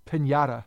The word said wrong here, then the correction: Pineda: Anyway, Ellsbury, Headley, and Pineda.